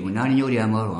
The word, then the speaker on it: Un año de amor: C'est Un año de amor. – Oui. – L'ai-je bien prononcé? – Oui, Un año de amor en espagnol, et ça veut dire un an d'amour.